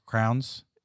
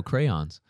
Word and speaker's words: crayons: How do you say crayons? Crowns? – Crowns? No, crayons.